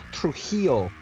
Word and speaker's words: Trujillo: Excuse me, it's pronounced Trujillo.